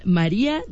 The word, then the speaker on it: Mariah: Saludamos también a Diego en Toluca, Mariah Carey, es que la escribió como María, perdón, yo lo pronuncié mal, Mariah.